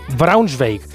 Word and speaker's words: Braunschweig: Braunschweig muy bien, está, muy bien, dicho, se, me ha entendido.